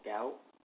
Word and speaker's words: Gaote: Their name was Miss Oliver and Dr. Gout. That's how you say it. – Gout, like gout in your foot? – Yeah. But he spells like G-A-O-T-E. – Oh, okay, good. It's not like a gout in your feet.